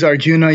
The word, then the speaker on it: Arjuna: It's a situation in which Arjuna, is that the right pronunciation? – Yeah. You can use Arjuna, you can use Arjun. The A can be dropped.